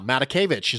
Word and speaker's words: Matakevich: I have made the mistake of emphasizing the wrong syllable in his name more times than I can count. I have said Matakevich so many times, and it's just, I'm putting the emphasis on the wrong syllable, Nate, and it's just, it's really problematic. So that one hurt my brain. – Yes. Yeah. Matakevich is what I'm supposed to be saying,